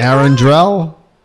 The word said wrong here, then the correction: Arendelle: No. – Arendelle. – Say it right.